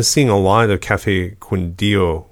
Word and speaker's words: Quindío: Quindío in the American. I think I need to look. – Oh, yeah. Quindío.